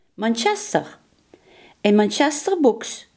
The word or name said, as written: ribfluweel